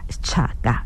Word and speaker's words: Chaka: Chaka. It is spelled, it's not incorrect, it's spelled with a C, as in Shaka with a C, C-H-A-K-A, but it is the very Shaka, king of AmaZulu that we know of.